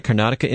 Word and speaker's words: Karnataka: Eric and Erica calling from Karnataka, I believe, did I get that right? Karnataka, India? Welcome, Zwettler family. – Well, that's absolutely correct.